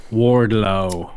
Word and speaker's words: Wardlow: Wardlow.